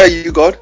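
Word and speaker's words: U-God: Is it You God? Am I saying that right? – Yeah, You God.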